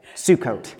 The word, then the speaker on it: Sukkot: I pronounced that right. Sukkot.